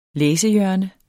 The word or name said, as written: læsehjørne